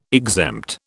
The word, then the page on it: exempt: British English